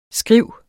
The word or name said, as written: skriv